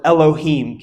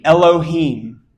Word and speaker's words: Elohim: That's the word Elohim. Can you say that with me? – Elohim. – If you're taking notes, that's spelled E-L-O-H-I-M.